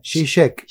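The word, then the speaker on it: Žižek: listo, es hora de, incluir incluirlo en el programa, un libro de Sisek que estás releyendo. – Estoy releyendo, lo, he leído cuando hacía mi tesis. ¿Cómo era que se pronunciaba en realidad el nombre? Shishek. Shishek. Shishek.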